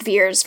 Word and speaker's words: Vers: It's told sort of non linearly, and we begin in medias res with uh, Veer, Vers, as she is called. – Pronounced Veers for some reason.